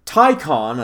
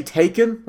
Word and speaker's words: Taycan: Part of me just wants to say Taycan, like I've been saying, to spite Porsche, because it's spelled, it's Taycan, Taycan. But apparently, Porsche says Taycan, like Thai food. And then con, like con artist.